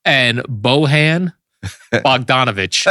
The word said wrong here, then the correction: Bojan: How do you say his first name? Well, he was on the Nets, Bojan Bogdanovic. – Ah, Bojan.